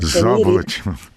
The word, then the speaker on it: Заболотів: У мене залишається одне, може, несерйозне, ви вважатимете запитанням, але де стоїть наголос у назві Заболотів? – Заболотів.